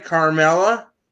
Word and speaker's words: Carmella: Did I say the name right? Carmella? – You got it.